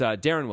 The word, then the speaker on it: Deron: The Mavericks are also trying to find a way to get rid of Deron Williams now. – It's Darren Williams. – Darren Williams. – Sure. – Okay, sure. – It looks like it would be pronounced Deron. It's not. It's Darren.